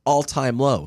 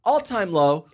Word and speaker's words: All Time Low: I've been putting the stress on the wrong word. It's not, it's All Time Low. – All Time Low. – Right. I've been saying All Time Low.